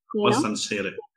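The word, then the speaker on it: Warsan Shire: It's Warsan Shira, Wansar Shire. – Warsan Shire.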